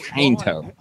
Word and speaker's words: Quinto: Sure it's the emphasis is on the I. Quinto.